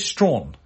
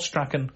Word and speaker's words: Strachan: They pronounce Strawn. Not Strachan, Strawn. It's one of those words that you there's no reason for you to believe that's how it's pronounced. Like a lot of British words. But it's pronounced Strawn, and that's that, really.